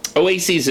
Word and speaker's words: oases: Now, by the way, oases is the plural of oasis. And I had to look it up. It's true. It is spelled, where is it? O-A-S-E-S, oases.